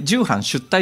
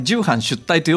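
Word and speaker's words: 重版出来: あれ重版出退って読むんですよ。でき重版できって書いてねあのよくできましたのでき書くんですけど、はい、重版重ねる版ができって書いてあれ重版出退と読むんですが、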